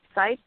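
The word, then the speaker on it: Seitz: It's Eileen Sites, like Eileen on my site. So it's L-E-E-N, S for Sam, E-I, T for Tom, Z for Zebra, .com.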